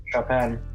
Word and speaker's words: Chopin: Yep, Chopin. – Oh, is that how you say it? – Yeah.